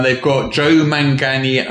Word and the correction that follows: Joe Manganiello: I can never say his name. I always mangle his name. – Joe Manganiello.